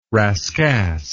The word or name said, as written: rascasse